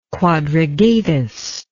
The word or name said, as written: quadrigatus